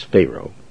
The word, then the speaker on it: Pharaoh: This joker here, Pharaoh, and that's the proper way to pronounce it, is Pharaoh, thought he was playing with God.